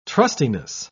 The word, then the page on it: trustiness: British English